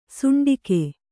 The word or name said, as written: ಷಂಡಕ